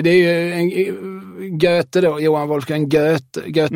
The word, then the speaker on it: Goethe: Nej, nej, det uttalas Goethe.